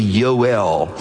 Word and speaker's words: Yoel: There's no J in the Hebrew alphabet. So his name would be Yoel, emphasis on the El, for Elohim.